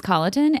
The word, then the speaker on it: Colloton: Well, I love it because my name's Colloton and no one can pronounce it.